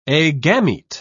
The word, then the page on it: agamete: British English